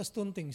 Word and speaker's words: stunting: Kalau enggak, orang tuanya lah yang sebetulnya yang, apa istilahnya sekarang ini, stunting atau stunting sih? Stunting. Tulisannya stunting. Bacanya? Stunting.